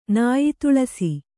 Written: ನಾಯಿತುಳಸಿ

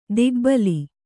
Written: ಧಕ್ಕೆಬಲಿ

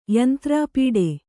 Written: ಯಂತ್ರಾಪೀಡೆ

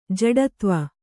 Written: ಜಡೆದೇವ

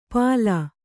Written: ಪಾಲಾ

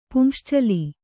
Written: ಪುಂಶ್ಚಲಿ

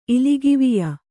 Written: ಇಲಿಗಿವಿಯ

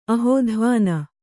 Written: ಅಹೋಧ್ವಾನ

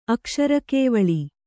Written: ಅಕ್ಷರಕೇವಳಿ

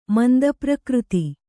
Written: ಮಂದಪ್ರಕೃತಿ

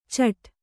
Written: ಚಟ್

